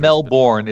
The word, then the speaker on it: Melbourne: Melbourne.